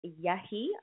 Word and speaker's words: Yehi: Up next is also a new artist, Yehi. I believe I'm saying that correctly. It's spelled Y-E-H-I.